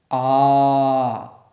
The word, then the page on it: ā: sanskrit